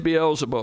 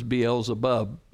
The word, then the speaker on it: Beelzebul: So who is Beelzebul? Or sometimes it's known, pronounced as Beelzebub.